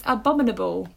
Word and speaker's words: abominable: At number five, it's Abominable, which is about Snowman, I guess. It's that kid's film. – Yeah, I can't pronounce that word. Abominable. – I can't there do that. Abominable. – It's like anemone.